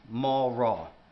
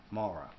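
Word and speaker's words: Mawraw (מרא): The Hebrew word there is actually pronounced Mawraw, phonetically M-A-W-R-A-W, Mara.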